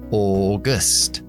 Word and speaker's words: August: Agosto, que es August. Segunda sílaba es schwa. August. August.